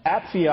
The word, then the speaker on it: Apphia: Notice also in verse 2, he addresses Apphia, I should say, that's how you pronounce that, and Archippus.